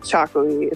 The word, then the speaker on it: txakoli: And Chocolaty is hard to pronounce. And I don't want people to be intimidated, but it's from the Basque region in Spain, and it's TXAKOLI. I hope I spelled that correctly. – Wouldn't have thought that there was an X in it. – I know, which I don't want people to be scared off by. It's pronounced Chocolaty. It's not too hard to say.